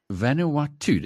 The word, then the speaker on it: Vanuatu: Vanuatu, is that the way you pronounce it, Nadia? – Yeah, I even checked.